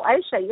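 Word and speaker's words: Ayşe: Okay, so if you want to get in touch, please help me pronounce your – I have a feeling I might be saying it wrong. – No, Aisha, you're saying it right. – Aisha, that's right.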